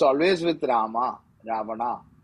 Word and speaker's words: Ravana: So Sanskrit, it is always Rama. It is Ravana. It is never Ravan.